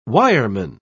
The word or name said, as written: wireman